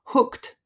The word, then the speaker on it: hooked: hooked, hooked. このくと、くとっていう発音がやっぱり難しいですね。